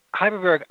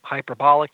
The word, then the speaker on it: hyperbaric: Okay, a lot of people mispronounce it. I'm going to go in the hy- hyperbolic chamber and whatever yep. they want. – You're not going to hyperbolic. It's hyperbaric.